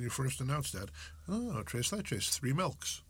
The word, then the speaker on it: tres leches: here, pronounce that for me. – Tres leches. – Thank you.